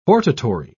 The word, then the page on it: hortatory: British English